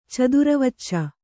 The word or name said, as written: ಚದುರವಚ್ಚ